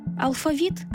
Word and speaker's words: алфавіт: Порівняємо. Алфавіт – російською. – Алфавіт.